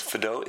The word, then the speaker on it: Fado: Our next stop is Fido, F-A-D-O. Pronounced F-apostrophe-D-O-E. I know this because I asked the waitress directly. I said, is it Fado's or Fado? She goes, Fado.